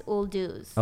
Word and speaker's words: Uldouz: How do you say it in Farsi? – In Farsi, it's uldouz. – Okay. – Uldouz. – So it's not too far from.